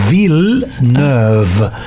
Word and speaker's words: Villeneuve: Villeneuve. Literalmente es como una ciudad nueva. Una ciudad nueva, Villeneuve.